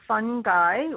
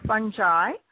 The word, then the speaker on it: fungi: First, you said that there are these two types of fungi or fungi. Can be pronounced either way, I believe.